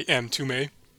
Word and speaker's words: Mtume: It's called Juicy Fruit by M. Tumay. I didn't know that's how you pronounced it. – It's spelled M-T-U-M-E.